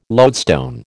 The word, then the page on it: lodestone: American English